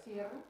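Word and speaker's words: Serra: How do you say Sierra? Sierra? – Sierra. – Sierra, thank you.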